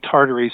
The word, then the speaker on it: Tartary: Actually, I think in the literature it's called Tartary, so it's from the Tartars, but which is a region in China. But yes, Himalayan Tartary buckwheat.